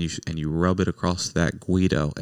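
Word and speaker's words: güiro: And then the other instructor, who is the director of the rondalla, is playing what is known as the guido. Is that how you say it, right? – Yeah, that's the way. – And it's a guido,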